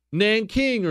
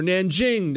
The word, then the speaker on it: Nanjing: Nanking or Nanjing, depending on how you want to pronounce it, this is about 6 years before that the rape of Nanking.